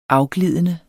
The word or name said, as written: afglidende